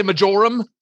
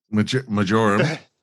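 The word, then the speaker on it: marjoram: Is that how you pronounce it in Australia? Majorum? – Majorum.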